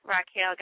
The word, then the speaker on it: Raquel: On Twitter, @Foxy_Roxy, and on Facebook, Raquel Geist.